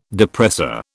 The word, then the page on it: depressor: British English